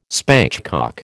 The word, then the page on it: spatchcock: American English